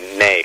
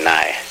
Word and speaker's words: ney: And I usually say ney, because that's the Turkish pronunciation of the instrument. You say nai.